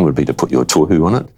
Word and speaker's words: Tohu: Tohu, always a hard one to pronounce. A lot of New Zealanders as well would say it tohu, but the tohu would be the sort of correct pronunciation, if you like.